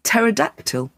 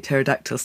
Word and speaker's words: Pterodactyl: I am telling you Pterodactyl starts with a P. – You know what? Every day is a learning day. – See, there you go. It's a silent P.